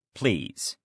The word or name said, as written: please